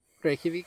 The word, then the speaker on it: Reykjavik: Reykjavik. Did I say that right? – Reykjavik? – Yeah, okay.